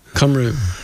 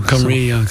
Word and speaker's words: Cymru: Cymru. – Cymru or Cymru. – Cymru, so. Cymru is right. Actually, you pronounce it better than I do.